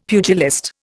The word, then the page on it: pugilist: Indian